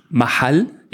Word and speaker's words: Mahal: Für Mahal, Mahal oder auf richtig auf arabisch Mahal, ich kann Mahal.